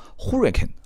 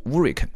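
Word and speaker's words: Huracán: 我曾经发音叫 Hurricane 啊，后来我打电话给兰博基尼的官方客服，他们读音叫 Hurricane 啊，Hurricane。但是这个韩老师曾经在汽车之家专门啊批评过我说这个发音不对啊，应该是读 Hurricane，西班牙语的一个发音 Hurricane 啊，那我就按照官方这个兰博基尼的客服的说法就读 Hurricane 啊。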